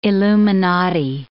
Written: illuminati

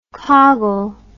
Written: coggle

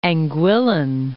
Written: Anguillan

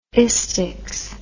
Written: istics

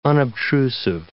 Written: unobtrusive

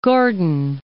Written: Gordin

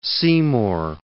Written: Seymour

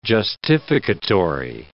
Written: justificatory

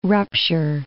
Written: rupture

